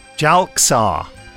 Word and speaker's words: Jalxar: Jalaxar. How do you spell it? I thought J-A-L-X-A-R. – Jalaxar. That's how we pronounced it.